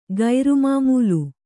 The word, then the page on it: ಕಾರ್ಮಮಲ: kārmamala